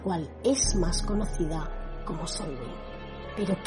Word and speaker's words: Samhain: Se pronuncia Samhain a pesar de que se escribe Samhain y significa fin del verano y realmente es la festividad de los muertos.